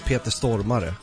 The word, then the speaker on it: Stormare: In Swedish, you say Peter Stormare. Mm-hmm. That's actually how you pronounce it.